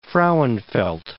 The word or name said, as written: Frauenfeld